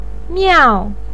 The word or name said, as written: miao4